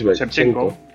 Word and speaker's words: Shevchenko: fue Arkady si lo digo bien, es Shevchenko. – Eh, como el jugador de fútbol.